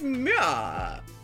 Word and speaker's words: MUA: That term is so funny to me, Mua. It's Mua. It took me forever to figure out how to pronounce it.